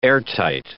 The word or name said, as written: airtight